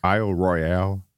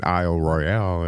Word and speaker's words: Isle Royale: Least visited national parks was Isle Royale out of Michigan in Lake Superior. – Mm-hmm. – And a Michigander chewed me out because it's pronounced there Isle Royal.